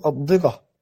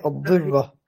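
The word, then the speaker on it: الضرّة: يعني أنا لو أنطق كما هي مكتوبة فأقول الضرة، لكن هي الضرة.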